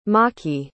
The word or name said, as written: machi